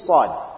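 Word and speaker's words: Sad (صاد): And very common mistake is Sad. You see, Sad, the pronunciation of Sad is very close to Sin.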